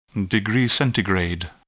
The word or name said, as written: degree Centigrade